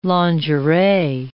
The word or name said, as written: lingerie